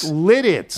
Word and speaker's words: Lititz: That's, I'm sorry, that's Lititz. I kept saying Lilitz earlier it, in the show with Wilbon. And it's Lititz? – It's Lititz.